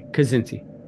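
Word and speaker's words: Kzinti: Kazinti.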